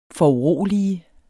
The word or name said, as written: FORUROLIGEDES